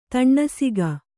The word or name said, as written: ತನಿಸೊಕ್ಕು